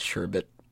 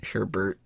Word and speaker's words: sherbet: I like how you pronounced it, sherbet. Like, it's always one of those weird words. Sherbet, sherbert.